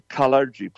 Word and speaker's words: Kalergi: The Kalergi Plan. Spelled K-A-L-E-R-G-I, Kalergi. That's how you pronounce it.